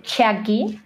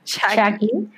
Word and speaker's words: Chucky: Exacto, y Miranda nos está acompañando como cuarto integrante, Chucky, así es como se pronuncia, según el estudio. Chucky.